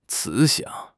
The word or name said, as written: 慈祥